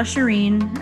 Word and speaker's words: Shirin: The Shirin, and it's spelled S-H-I-R-I-N.